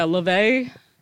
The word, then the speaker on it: LaVey: Yeah, LaVey.